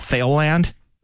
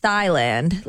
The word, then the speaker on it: Thailand: By the way, did I tell you that until I was 10 years old, I used to call it Thailand? – What? – Thailand was Thailand until a teacher finally corrected me. – Thailand? – Yeah, Thailand, because it's spelled T-H-A-I-L, Thailand.